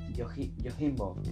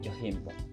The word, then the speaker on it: Yojimbo: Tiene una en el 61 que se llama Yojimbo. Está bien pronunciado con J. – Supongo, sí. – Sí, mira. – Sí, está bien. – Yojimbo.